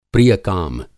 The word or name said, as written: प्रिय-काम